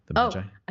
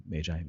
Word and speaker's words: Magi: Magi. I thought you said it differently. That's why. – No, it's Magi, Magi. There's multiple pronunciations.